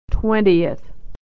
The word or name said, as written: twentieth